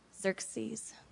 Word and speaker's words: Xerxes: The first character we meet is King Ahasuerus, And as he's also known as King Xerxes. Xerxes. I got it.